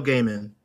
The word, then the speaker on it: Gaiman: Neil Gaiman.